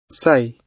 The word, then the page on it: say: British English